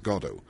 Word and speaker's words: Godot: The only thing he insists on is that it's pronounced Godot. And he said, with a twinkle in his eye and a wink at the questioner, because it sort of sounds like God.